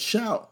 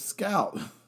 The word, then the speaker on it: Skout: And then we have Real You, R-E-A-L-U, Real You. Then you got Nearify, N-E-A-R-I-F-Y. And then you have Shout, I did not say that right, it's Scout. If we have Scout, S-K-O-U-T. And then we have Next Door, like literally Next Door, N-E-X-T-D-O-O-R.